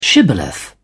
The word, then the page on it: shibboleth: British English